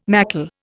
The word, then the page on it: macle: Indian